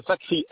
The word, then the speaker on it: Fakri: Monsieur Fakri Madani. – Ouais. Comment s'écrit le nom de famille? – Fakri, F-A, oui. – K-R-I, plus loin Madani, M-A-D-A-N-I.